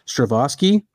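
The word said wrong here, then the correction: Strahovski: who is Strahowski. – Strahowski.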